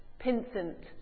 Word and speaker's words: Pinsent: Pinsent.